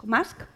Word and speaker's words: Musk: Vamos a hablar sobre desinformación, sobre cuentos, sobre mentiras, sobre quizás Elon Musk, ¿Musk? ¿Cómo se pronuncia? – Musk. – ¿Musk? – Musk.